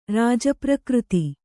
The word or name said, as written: ರಾಜಪ್ರಕೃತಿ